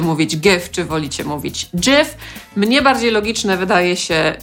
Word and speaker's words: GIF: Rzeczywiście twórca tego formatu tłumaczy, że należy to wymawiać jako GIF.